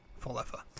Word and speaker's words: Falefa: IKF, IFK, Falafel, Falefel. Falefa. Falefa.